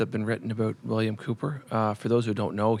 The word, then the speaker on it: Cowper: His name was actually pronounced William Cooper, and a lot of people pronounce it William Cowper, but it's William Cooper.